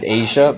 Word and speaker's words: Agia: I know a friend who's named Asia, but not spelled Asia. It's spelled A-G-I-A.